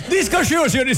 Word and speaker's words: Disclosure: Torna con Disclosure. Si pronuncia così? – Disclosure, signori. Disclosure.